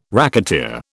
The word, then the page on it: racketeer: British English